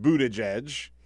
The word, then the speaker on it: Buttigieg: It's pronounced Buttigieg uh, as. Hmm? They're, they're, he's selling shirts now. Have you seen the shirts? No. So it says boot, edge, edge.